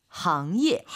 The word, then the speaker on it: 行业: 항예.